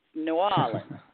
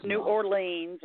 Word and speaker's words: New Orleans: Yeah, I have to compliment you, and as a fellow Southerner, you know how to pronounce the name of the city. It's New Orleans. – New Orleans, that's right. – Yeah, it's not New Orleans or anything like that.